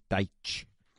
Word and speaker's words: Dyche: It was Sean Dyche. I've been informed that's how you say his name, Dyche.